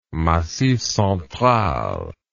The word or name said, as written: Massif Central